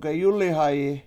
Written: geyUlihayi

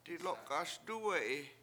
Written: dilOk7ASduwe7i

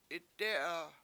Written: itdE7a

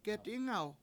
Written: get$Ingaw

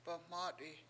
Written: baMAt7i7

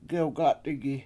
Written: gewgAt7igi

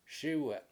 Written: SIwe7

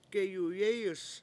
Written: geyuyEEyus

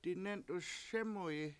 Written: dinEnt$uS SEmuyi

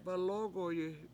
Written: balOOgoyi

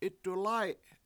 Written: 7itdulAy7

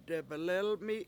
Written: debelElmi7

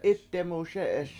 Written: 7itdemuSE7eS

